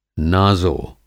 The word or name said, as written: नाज़ो